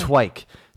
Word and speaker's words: Twike: Is that how it's pronounced again? Am I remembering this correctly? – The Twike. T-W-I-K-E. Yep.